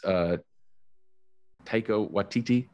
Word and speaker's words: Taika Waititi: I saw a quote from somebody the other day that said, you know, like writing, I always say his name wrong. It's Taika Waititi. Taika? Yes.